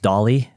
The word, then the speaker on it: DALL-E: I think it's pronounced DALL-E, D-A-L-L-E.